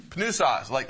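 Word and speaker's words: pneuma: Pneusos. In English, we would have a silent P.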